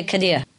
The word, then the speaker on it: Khidr: Okay, Qadir. With I in the middle of it.